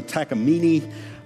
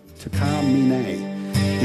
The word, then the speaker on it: Takamine: Some people call them a Takamine, some people call them a Takamini, uh, but it's actually a Japanese mountain it's named after. Uh, Takamine, Takamine is what they're actually pronounced as.